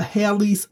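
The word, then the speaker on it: Halley's: I took a class a few years ago that required me to obtain a Haley's Bible Handbook. That's spelled H-A-L-L-E-Y apostrophe S.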